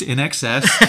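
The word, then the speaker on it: INXS: Pronounced NXS.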